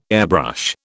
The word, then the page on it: airbrush: British English